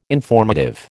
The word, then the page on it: informative: American English